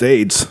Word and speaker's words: AYDS: That's what it was at the beginning, was AYDS. Okay. But it's still pronounced AIDS.